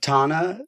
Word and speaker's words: Tana: Is that because do you still rep Tana? – Yes.